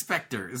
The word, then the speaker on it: Spectre: The E is silent. It's just Spectre.